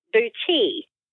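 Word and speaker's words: boutis: Talking about new techniques, I have recently learned how to do bucci.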